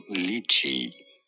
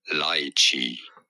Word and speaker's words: lychee: No, it is not lychee, but rather lychee.